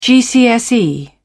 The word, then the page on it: gcse: British English